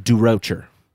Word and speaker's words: Durocher: Leo Durocher. – Capicola. – Durocher? – Durocher.